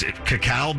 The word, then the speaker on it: cacao: I don't know how to pronounce it, but it's cacao beans, C-A-C-A-O.